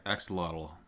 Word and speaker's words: axolotl: Axolotl. – Thank you. Axolotl? – Mm-hmm. Okay. Oh, yeah. – Never heard of him, but that's how you say it.